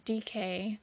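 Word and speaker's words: Dike: So the goddess Astraea, or DK, it's spelled like Nike with a D.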